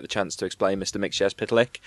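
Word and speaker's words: Mxyzptlk: which is the reason I know how to pronounce Mixyazpitalik is that in John Byrne's Superman run, he has him spell it out phonetically so that you know how to say it.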